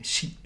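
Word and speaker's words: Shi (四): Ähm, dann ähm, ist es nämlich so, dass äh, die Aussprache der Zahl 4, ähm, Shi ist im Japanischen und Shi wird auch gleichzeitig,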